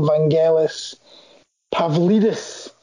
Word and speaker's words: Vangelis Pavlidis: I don't know about the pronunciation here. You'll correct me if I'm wrong. Vangelis Pavlidis. – Yeah, that's right.